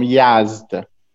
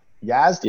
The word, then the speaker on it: Yazd: I'm from Yazd. – Yazd, yes.